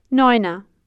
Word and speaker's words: Neuner: Neuner. Da schreibt man N-E-U-N-E-R.